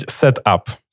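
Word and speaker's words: Setapp: SetUp, правильно говорить SetUp? – Можно говорить SetUp.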